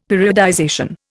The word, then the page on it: periodization: Indian